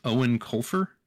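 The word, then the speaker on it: Eoin Colfer: I had to look up how you pronounce the author's name because he is Irish, I believe. – Yes. – But it's Owen Colfer.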